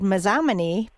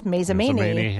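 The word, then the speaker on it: Mazomanie: And so for a few years, I said Mazamani because it looked like it should be, but no, it's Mazamani.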